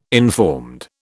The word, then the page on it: informed: British English